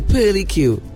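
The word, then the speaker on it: purlicue: So it's called the, I think I'm pronouncing it correctly, right? – Yeah. – Pearly Q. The Pearly Q. P-U-R-L-I-C-U-E.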